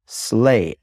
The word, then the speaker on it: sleigh: Think of Santa Claus and his Sleigh, S L E I G H, Santa and his sleigh, pronounced the same as slay, S L A Y, to violently kill, and the other one is Santa's sleigh.